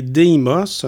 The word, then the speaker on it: Deimos: Maintenant, la planète Mars, deux lunes, Phobos et Deimos.